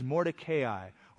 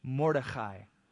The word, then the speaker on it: Mordecai: Now in Shushan the citadel, there was a certain Jew whose name was Mordecai, or if you prefer the Hebrew pronunciation, Mordechai. Let's just say Mordecai, we're Americans.